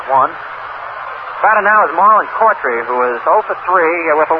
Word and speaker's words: Coughtry: Cawtry is the way fellow pronounces his name. It's spelled C-O-U-G-H-T-R-Y, but he pronounces it Cofftree.